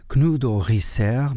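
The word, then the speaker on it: Knudåge Riisager: Knudo Risser, mas como acontece com outros compositores dinamarqueses, pronuncia-se bem diferente da forma como se escreve, pelo menos para a nossa visão portuguesa. Escreve-se como Knudeg, com uma símbolo por cima do A, mas escreve-se como Knudega Rissager e pronunciam Knudo Risser.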